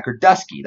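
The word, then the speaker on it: Girdusky: Neither did Ryan Gerdinsky. Gerdusky? Yeah, Gerdusky. That's how it's pronounced.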